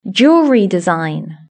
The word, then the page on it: jewellery design: British English